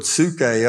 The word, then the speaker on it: psuche: But soul predominantly, and I challenge you, get a concordance and look the word psuche up. It's spelled P-S-U-C-H-E.